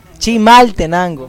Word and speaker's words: Chimaltenango: Chinaltenango. – Chinaltenango. – No, sin N. Chinaltenango. Chimaltenango.